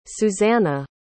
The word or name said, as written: suzanna